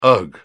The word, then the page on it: ugh: British English